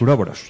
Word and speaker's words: uroboros: Y bueno, pues en la portada hay un a ver si lo digo bien, ouroboros. Los uroboros. – Eso,